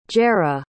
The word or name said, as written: jerah